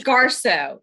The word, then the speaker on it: Garso: It's Garso. G-A-R-S. It's like G-A-R-S-O. Garso. It's how it's pronounced.